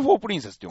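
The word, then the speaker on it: 円盤皇女: ね、 UFO プリンセス、ワルキューレ。 – ワルキューレ。 – 時と夢と銀河の宴第2話。 – え、ちょっと待って、これなんて読むの？ – え、UFO プリンセスです。円盤工場とか円盤工場って書いて、そうそうそうそう、 UFO プリンセスって読む。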